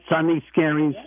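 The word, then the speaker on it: Sunday Scaries: Did I say that right, Sunny Scaries? – You did, yeah, absolutely.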